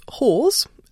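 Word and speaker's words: Hoare's: which is Hawes. Obviously, saying saying it on a podcast, I should point out it's spelled H-O-A-R-E apostrophe S.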